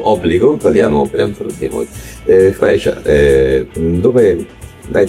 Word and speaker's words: Freya: E infatti Freya, che è la pronuncia corretta, sì, Freya, è la moglie di Odino, quindi siamo nella mitologia norrena.